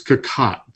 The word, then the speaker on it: Cocotte: And I've always found myself not pronouncing that properly, but having talked to Kat in this interview, I now know without thinking it's Cocotte. That's how she pronounced it, where she used to cook.